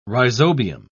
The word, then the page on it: rhizobium: British English